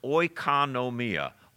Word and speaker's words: oikonomia: Remember, it's pronounced oikonomia.